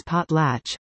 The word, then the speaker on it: potlatch: ban, pronounced as potlatch.